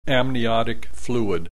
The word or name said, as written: amniotic fluid